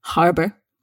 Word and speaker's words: harbour: You must pronounce the H, harbour.